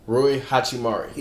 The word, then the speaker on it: Rui Hachimura: Harold or the guy in uh, Washington, I can never pronounce his name correctly. – Um, oh. Roy, Roy. Rui Hachimura.